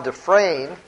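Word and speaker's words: de Fraine: You have it expressed, for example, by the Jesuit writer Jean Dufresne, that's spelled capital D-E, capital F-R-A-I-N-E, not John Frame, but Jean Dufresne.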